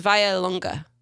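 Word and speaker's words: Vallelunga: I'm just going to spell it. V-A-L-L-E L-U-N-G-A. I feel like it's Vallelonga. Anyways, the first was the Vallelonga, and then the Mangusta, the ever-popular Pantera, and the Guaya.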